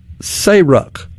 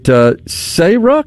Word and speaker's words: SARUC: I think he may be down there, and they pronounced this acronym, SARUC. Is that right, Lucian? Southeastern Association. Want to make sure I get that right, of Regulatory Utility Commissioners. Yeah, he just left that meeting. So appreciate you letting us know that. Is it SARUC? Yeah, that's what you said phonetically in his description there.